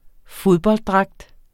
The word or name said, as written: fodbolddragt